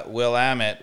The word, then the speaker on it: Willamette: So, this is a Willamette, which rhymes with damn it.